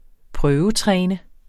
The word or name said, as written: prøvetræne